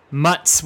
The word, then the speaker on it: Muzz: pronounced with Mutz with the ZZ like in pizza, and probably other things.